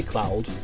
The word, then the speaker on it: Claude: It should be Cloud, because it's because of the French nature of the game.